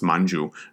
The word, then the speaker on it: Manchu: So Manjushri, in Manchu, the word Manchu is actually pronounced Manju, so it's a little, it's even a little bit closer to Manjushri.